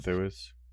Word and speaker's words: Thewlis: like even like David Thewlis, I don't know how to say his name. – Thewlis.